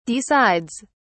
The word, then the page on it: decides: British English